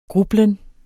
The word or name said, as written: grublen